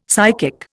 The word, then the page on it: psychic: Indian